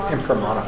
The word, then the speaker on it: Imprimatur: You want to look for a Bible that has the, I'm not going to pronounce it right, the Imprimatur.